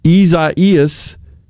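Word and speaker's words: Isaías: In a nutshell, Ezias is the Spanish or Portuguese pronunciation of Isaiah, one of the great biblical prophets.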